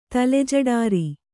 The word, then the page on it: ತಲೆಜಡಾರಿ: kannada